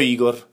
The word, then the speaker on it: Igor: No, non si pronuncia Igor, è proprio Igor.